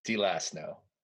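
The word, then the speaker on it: D'lasnow: Sometimes there's an accent like DeLasno, so I was like... – Sure. Not to my knowledge in this case, but I've heard it pronounced many different ways by many different people.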